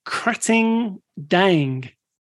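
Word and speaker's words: Krating Daeng: There's another one called Krating Dang. – Well pronounced.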